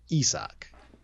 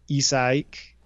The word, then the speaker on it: Isaac: So in the pronunciation guide and hearing that Shepherd and what's going around the conventional wisdom, it is Isak, or maybe even three syllables, which would be Isak.